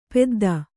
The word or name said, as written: ಪೆದ್ದಿ